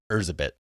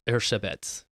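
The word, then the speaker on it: Erzsébet: Like Elizabeth, the way that her name is spelled, it's Erzabet. – Yeah, it's Erzabet.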